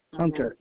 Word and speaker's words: Hunter: S-T-E-V-E. – Last name, please. – Hunter, H-U-N-T-E-R.